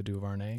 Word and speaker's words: DuVernay: Lindsay and I watched, we did kind of like a two-night marathon where we watched 13th, the documentary by Ava DuVernay.